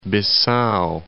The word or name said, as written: Bissau